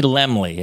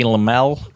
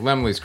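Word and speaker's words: Laemmle: I mean, I saw it at the, I'm going to say Lemley. Am I pronouncing that correctly? – Yes. Yes. – Because it looks like it might be Lemel. – No, Lemley's correct.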